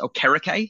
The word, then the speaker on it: Okereke: Everyone's calling him Okereke, and apparently he said it's pronounced Okereke.